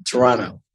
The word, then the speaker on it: Toronto: Hence the pronunciation, Toronto. – Yeah, he didn't put the T in there, did he?